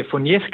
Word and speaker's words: Fournier: Så har du faktisk forsket og skrevet PhD om netop Fournier's gangren. Udtaler jeg det rigtigt? – Vi kalder det Fournier's gangren, men det er næsten det samme, okay.